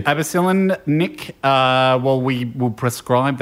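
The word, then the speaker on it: Abbocillin: Abacillin? – Abacillin.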